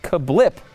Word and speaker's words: CABLP: It's actually apparently pronounced Kablip, spelled though C-A-B-L-P.